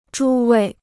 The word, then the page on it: 诸位: zhū wèi